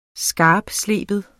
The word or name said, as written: skarptsleben